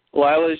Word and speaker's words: Lila: Lila? – Lila. – I said it right. Ooh. – You did. – Lila's just using you,